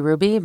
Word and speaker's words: Hruby: So my handle is at Aubrey Ruby, but my last name is spelled H-R-U-B-Y.